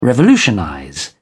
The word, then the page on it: revolutionize: British English